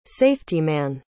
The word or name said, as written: safetyman